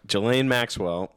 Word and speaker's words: Ghislaine Maxwell: Ghislaine Maxwell has just been cleared mentally fit to stand trial after asking for a second opinion.